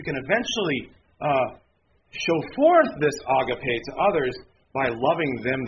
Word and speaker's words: agape: They always call it agape. It's pronounced agape.